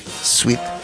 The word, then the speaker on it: Swit: Ojo que Sweet se escribe de una manera muy curiosa. Yo también la primera vez, Sweet, hablando con Ignacio, le dije, suena como dulce, pero no. Sí, suena como dulce, pero no se escribe así. Se escribe S-W-I-T. S-W-I-T.